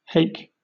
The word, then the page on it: hake: British English